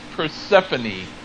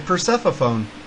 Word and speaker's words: Persephone: Um, it's Persephone. – I was gonna say Persephone.